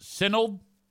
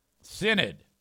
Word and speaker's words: Synod: Adam says it's pronounced Sinold. Sinold? Synod. You wrote Sinold. Synod.